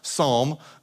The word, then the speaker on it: Psalm: So when we say Psalm, the P is silent, like it should be in our bathrooms too.